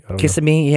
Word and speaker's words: Kissimmee: February 1st, a man actually punted a rabid coyote in Kissimmee. Kissimmee? – Kissimmee, yeah, yeah. I think you might have said it right the first time.